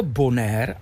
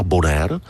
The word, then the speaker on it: Bonaire: Velmi často se mu říká Bonaire, ale protože to patří pod Holandsko a pod holandský Karibik, tak ta výslovnost se opírá víc o holandštinu a tím pádem místní mě opravili. Já jsem si myslel, jak tady v Parajesky říkám Bonaire, jak to má znít. Oni mi říkali, my se tady jmenujeme Bonaire.